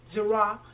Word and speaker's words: Zera: The singular word seed used in Genesis 3:15 is from the Hebrew word translated from word 2233, and I will spell it Z-E-R-A, Zerah, and means seed, fruit, plant, sowing time, prosperity.